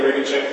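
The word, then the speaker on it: Regenscheit: Daniel Regenchek for the appellant.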